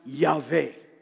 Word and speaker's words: Yahweh: The Hebrew name of God is pronounced Yahweh. Some say Yahweh, but Yahweh.